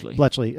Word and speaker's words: Bletchley: Bletchley.